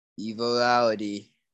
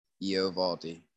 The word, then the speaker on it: Eovaldi: And my boy, Evaldi, I can't say his name. – Eovaldi.